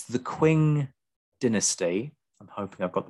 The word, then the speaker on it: Qing: I have to say that for some reason it is pronounced Qing. – Oh, it's Qing.